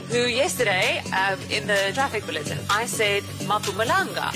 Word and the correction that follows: Mpumalanga: Yes. – I said it so confidently. Polly looked at me and she said, is that what you call it? And I was like, yes. They educated me and said it's Mpumalanga.